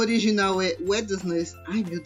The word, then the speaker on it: Wednesday: Filha, o D é mudo, é Wednesday. – Wednesday. – Isso. – Foi. – Então, o D não, não se pronuncia.